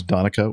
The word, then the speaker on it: Donnchadh: My name is Donica, which is spelled really, really weird. Donnchadh from Ireland. – Gaelic spelled. – It's Gaelic. Okay.